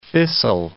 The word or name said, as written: fissile